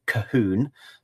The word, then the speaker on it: Colquhoun: That's Colquhoun. It's much easier to pronounce than it looks. To pronounce it, you just have to take the opening C and the final four letters. That gives you Cahoon.